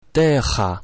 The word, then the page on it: terra: french